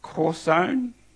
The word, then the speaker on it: chazon: It's spelt C-H-A-Z-O-N in the English spelling, but it's pronounced corzone, something like that anyway.